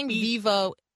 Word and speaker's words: Vivo: Vivo? Have I pronounced Vivo correctly? – You got it exactly right.